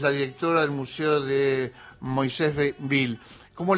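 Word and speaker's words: Moisés Ville: Sí, en realidad nosotros ahora pronunciamos Moisésville. Pero el origen es en hebreo y es Kiryat Moshe. Ese es el nombre original, Kiryat Moshe, que se lo denominó así al pueblo el rabino Aarón Halevi Goldman, que fue el jefe espiritual del primer grupo.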